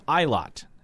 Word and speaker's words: Ilott: But let's start with Callum Ilott. That is apparently how you pronounce it.